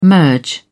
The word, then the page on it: merge: British English